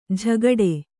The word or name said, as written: ಝಗಡೆ